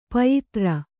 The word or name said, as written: ಪಯಿತ್ರ